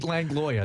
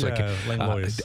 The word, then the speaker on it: Langlois: Pete Langlois. It's Langlois. I pronounced that S. The origin of that is Langlois. Pierre Jean Langlois. It's a French name. But we just say Langlois because Langlois is just, it seems too complicated for people.